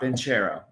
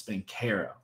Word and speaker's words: Banchero: I know it's not Banchero, it's Banchero.